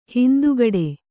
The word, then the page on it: ಹಿಂದುಗಡೆ: hindugaḍe